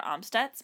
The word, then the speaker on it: Amstutz: You can find me on pretty much all social platforms at Sandra Amstutz.